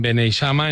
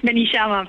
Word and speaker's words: Beneixama: Ella tiene 56 años, vive en Beneixama, en Alicante, y es profesora de FP Agraria. ¿Me equivoco sí. en algo? Seguro que el nombre del pueblo lo he dicho mal. – Beneixama, pero vale.